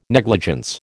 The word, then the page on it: negligence: American English